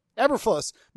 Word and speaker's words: Eberflus: Is it Eberflus? Eberflus? How do you say it, Pete? You're the pronunciation guy. Did I get it right the first time? – Eberflus.